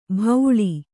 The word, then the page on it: ಭೌಳಿ: bhauḷi